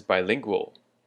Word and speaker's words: bilingual: And our next word is bilingual. Bilingual. And bilingual, of course, means to do with two languages or someone who speaks two languages. So the emphasis is on the second syllable, bilingual.